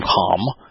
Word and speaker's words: Cham: Ham, C-H-A-M, so it's the hard C-H-A-M, Ham, which means passionately idolatrous sun worshiper, who was the father of Canaan.